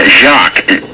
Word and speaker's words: Jacques: This is a French name, and it's pronounced Jacques, J-A-C-Q-U-E-S, and the last name is Santer, S-A-N-T-E-R.